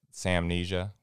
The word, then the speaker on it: Salmnesia: Uh, so we're calling it Salmnesia, which is spelled S A L M N E S I A, Salmnesia.